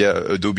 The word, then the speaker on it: Adobe: Comme tu l'as dit, Adobe, parce qu'on dit Adobe ici. – Oui, oui, tu as raison. Mais même en France, la prononciation officielle, c'est Adobe.